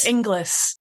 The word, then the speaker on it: Ingalls: But the reason for that is that no one, when it's written, people don't say it right. So it's annoyed me all my life. So I just decided that I would use my married name. – How do they say it? – Inglis. Is it spelled I-N-G-L-I-S?